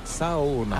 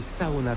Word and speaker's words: Saona: Loona, l'île Sona. Et comment on dit Sona? – En fait, on prononce chaque lettre, donc c'est Saona. – Ah oui, Saona, c'est vrai qu'on prononce chaque lettre.